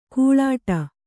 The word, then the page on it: ಘೀಳಿಡು: ghīḷiḍu; kannada